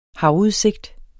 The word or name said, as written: havudsigt